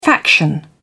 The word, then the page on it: faction: British English